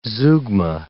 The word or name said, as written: zeugma